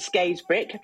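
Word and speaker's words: Scarisbrick: So thank you for pronouncing it Skaysbrick, because that's how it should be pronounced, even though it's spelled Skaysbrick.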